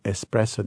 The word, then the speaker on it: espresso: Often you hear from American that they mispronounce the word espresso. They say espresso. – Right. – Okay. So even practicing a little bit. Espresso. – Espresso, espresso.